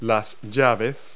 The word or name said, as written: wrenches